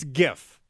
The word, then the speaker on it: GIF: The first word in GIF is graphic. You don't pronounce it draphic. So, thus, it's GIF.